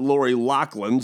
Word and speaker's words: Lori Loughlin: And then the other name is Lori Loughlin, or as her family pronounces it, Lori Loughlin, so I guess therefore it's Lori Loughlin.